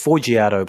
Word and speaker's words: Forgiato: Oh, I think it's Forgiato Blow. – Forgiato Blow. – Soft G.